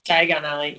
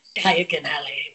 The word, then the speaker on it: Diagon Alley: Diagon Alley, yeah. Diagon Alley.